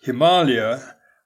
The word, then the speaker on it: Himalaya: And the general consensus nowadays seems to be that Himalaya, with a long first A, identifies a region, whereas Himalayas is a collection of mountain ranges.